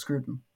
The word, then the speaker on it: Scruton: But It's just Scruton. – Scruton. Yeah. So it's not Scruton, like Crouton. Okay. – To my knowledge, no.